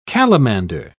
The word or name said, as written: calamander